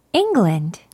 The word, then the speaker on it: England: England. – England 라고 합니다. 첫 번째, ing 부분 강하게 발음해서 England. England 라고 발음하면 되는 거예요.